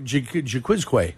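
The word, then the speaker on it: Jaquiski: Jaquizki. – Jaquizki or Jaquizque? Is that what it was? – Like Jamiroquai.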